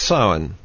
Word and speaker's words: Samhain: And it looks like Samhain or something similar to that, but it's pronounced Samhain.